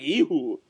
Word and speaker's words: Jehu: We're going to be talking about Drive Like Jehu coming back. Or if you want to pronounce it in Hebrew or in Aramaic, Drive Like Ehu.